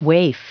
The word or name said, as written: waif